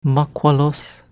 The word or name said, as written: Mahqualos